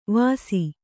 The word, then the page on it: ವಾಸಿ: kannada